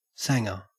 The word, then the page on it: sanga: Australian English